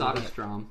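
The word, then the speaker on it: Söderström: Holy cow, how do you say Soderstrom. – That? Soderstrom. And Dennis Whedon, collectively known as Deniton Games.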